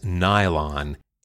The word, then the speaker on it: nylon: Then DuPont tried N-I-L-O-N, but how do you really pronounce it? They finalized the name as Nylon. N-Y-L-O-N.